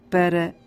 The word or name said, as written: para